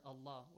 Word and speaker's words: Allah: So, when the letter Lam in the word Allah or Allahumma is preceded by a Fatha, it should be pronounced? – Heavily. – Not lightly, not Allahu, no.